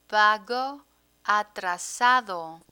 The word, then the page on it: pago atrasado: Spanish